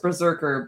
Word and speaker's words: BRZRKR: I know nothing about this comic, which is, it's pronounced Berserker, but it's spelt B-R-Z-R-K-R, all caps.